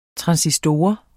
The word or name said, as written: transistor